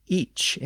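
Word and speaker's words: ic: You might remember from earlier episodes that it was originally pronounced each in Old English, usually spelled I-C or I-C-H.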